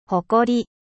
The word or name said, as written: fier